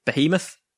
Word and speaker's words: Behemoth: So Cherub, Eruption, Behemoth, Behemoth, Behemoth. Behemoth. Behemoth. No, Behemoth. – Behemoth. Yeah, Behemoth. Behemoth. – So it's a silent H.